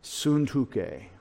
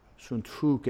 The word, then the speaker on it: Syntyche: And I encourage, this is going to be harder for you, S-U-N-T-U-C-H, long E, Syntyche. Syntyche. Syntyche is how they said her name.